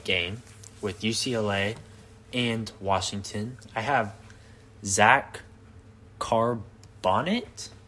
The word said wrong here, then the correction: Charbonnet: I think it's Charbonnet.